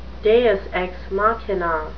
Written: deus ex machina

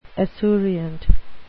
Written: esurient

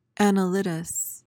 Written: annelidous

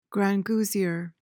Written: grangousier

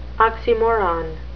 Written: oxymoron

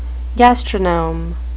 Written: gastronome

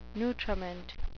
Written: nutriment

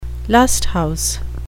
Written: lust-house

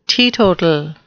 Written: teetotal

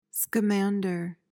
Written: Scamander

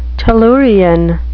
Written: tellurian

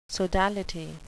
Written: sodality

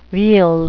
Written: weal

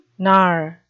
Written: knar

